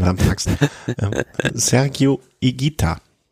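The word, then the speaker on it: Higuita: Also das H ist stimmlos und das U ist stimmlos. Ähm, hat mir mein kolumbianischer Kollege erklärt. Ähm, und der, er hat dann auch noch den, die Bonusinformation gebracht. Ähm, das ist, da gibt's gibt's einen Fußballer, Fußballtorhüter, der dann auch immer falsch ausgesprochen wird, weil die sprechen, die meisten Reporter sprechen den falsch auf. Also das heißt Igita, also stimmloses H und stimmloses U.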